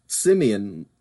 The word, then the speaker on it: Simeon: It's pronounced Simeon, like the monkey.